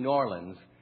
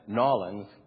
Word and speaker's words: New Orleans: New Orleans, or I should say it right, New Orleans.